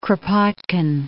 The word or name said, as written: Kropotkin